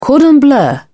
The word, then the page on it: cordon bleu: British English